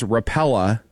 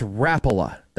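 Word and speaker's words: Rapala: I thought for years, because somebody close to me would pronounce it as Rapella, it's Rapella, the popular fishing lure brand.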